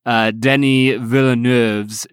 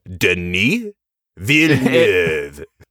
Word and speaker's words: Denis Villeneuve: We literally spent about, I don't know, 10 minutes before we recorded, like practicing Denis Villeneuve's name, because it is not easy to pronounce.